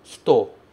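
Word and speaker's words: hito (人): Aqui, esse kanji que aparece no meio se pronuncia hito. Hito. Ele significa pessoa. Lá em cima, ele se pronunciava jin, e a gente colocava no final de um país para fazer a pessoa que nasceu naquele país. Mas quando vem sozinho, esse kanji é lido hito, e significa pessoa.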